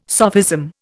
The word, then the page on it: sophism: Indian